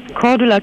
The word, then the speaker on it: Cordula: Cordula Kine.